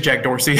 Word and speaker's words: Dorsey: Jack Darcy. Is that a Midwestern thing? I think it's Jack Dorsey.